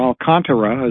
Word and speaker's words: Alcantara: And now the Dodgers are talking about Sandy Alcantara, or Alcantara as they pronounce it.